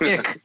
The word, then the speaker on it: iQ: The Scion IQ, which is pronounced ick, by the way.